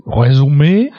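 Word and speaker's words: résumé: Resume? – Resume, which is an astonishingly Frenchly pronounced word in a...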